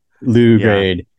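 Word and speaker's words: Lew Grade: I was going to say, it's pronounced Low. – It's Lou Grade.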